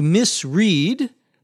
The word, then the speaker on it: misread: One reason could be that the pilot misread, M-I-S-R-E-A-D. To misread, the past tense is spelled the same but pronounced differently, misread, means to read or look at something, but not do it carefully.